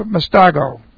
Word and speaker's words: mastigo: All right, this is the present active indicative of mastago. Okay, let's take a look at this word. Okay, M-A-S-T-I-G-O, long O.